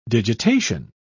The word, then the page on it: digitation: British English